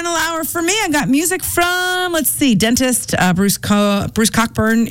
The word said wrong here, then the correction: Cockburn: is coming up. Coburn, I always say that wrong, and they always yell at me for that.